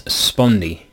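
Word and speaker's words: spondee: I believe the proper pronunciation for this next word is spondi.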